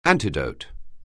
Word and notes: antidote: anglais

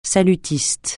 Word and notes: salutiste: francais

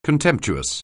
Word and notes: contemptuous: anglais

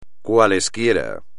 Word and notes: cualquiera: espagnol

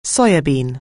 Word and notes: soya bean: anglais

